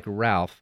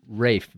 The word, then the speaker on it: Ralph: His first name is spelled like Ralph, but they pronounce it Ralph Vaughan Williams. – That's a British pronunciation.